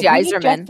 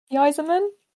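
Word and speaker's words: Yzerman: The Yeiser plan. The Yeiser plan? – Is it Yeiser? Yeiser? Yeiser? I don't know how you say his name. It's a hockey name. – It was Yeiserman. Yeiserman. – Yeiserman? – Yeah.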